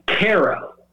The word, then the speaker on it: Cairo: It's spelled exactly like Cairo. Oh wow! Right, like, but it's Cairo is how they pronounce it, and it's in Illinois.